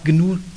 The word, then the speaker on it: GNU: Die können das so ohne weiteres nicht aussprechen. Im Englischen wird dann meistens so ein GNU daraus. Hier, ja, jetzt gibt es ja auch die Free Software Foundation Europe. Wir können es uns jetzt also erlauben, einfach GNU zu sagen, so wie man das als Europäer halt aussprechen kann.